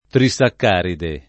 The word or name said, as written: trisaccaride